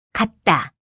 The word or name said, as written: 같다